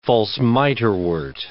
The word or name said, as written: false miterwort